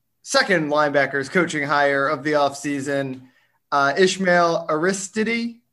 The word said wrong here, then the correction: Aristide: Aristide. We've gone over how to pronounce it. We've decided Aristide.